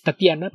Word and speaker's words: Tatjana: Entonces es Tatiana, pero se escribe con J, se escribe Tatjana. – Sí, pero la J va antes de la T, si mal no recuerdo. – Según yo, no. – Ok, entonces es Tatjana. – Se escribe Tatjana, pero sí, Tatiana.